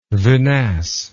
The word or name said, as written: vinasse